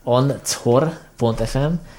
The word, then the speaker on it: anchor.fm: Ez ugye az encore.fm per filmvilág, tehát nem az encore, mint fájlmegosztó, hanem az anchor.fm.